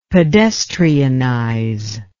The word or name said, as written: pedestrianize